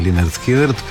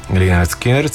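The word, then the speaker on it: Lynyrd Skynyrd: Ето го и Free Birds, великата песен на една от, разбира се, великите песни на Линард Скинърът през 73-та. Скинърът все още са млада южняшка рок група от Джаксонвил, Флорида. А дебютният му албум носи прочутото заглавие Pronounced Leonard Skinnard.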